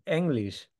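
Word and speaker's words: Englisch: You need to consider that the letter E is pronounced quite different in German. So this one would be English.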